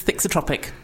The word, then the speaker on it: thixotropic: and it makes use of a property called thixopathy. – What's it called? Basically, I'm trying to make you pronounce it again. – I know, it was wrong. The myofascia is thixotropic, which means when you apply warmth and pressure, which comes from our hands, it actually changes state and it becomes more fluid.